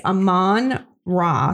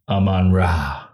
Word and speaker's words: Amon-Ra: What is his name? Ramon? – I took great pleasure in the fact that I said it right. – St. Brown? – It's Amon-Ra St. Brown. – Amon-Ra. – Yes.